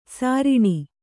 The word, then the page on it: ಸಾರಣ: sāraṇa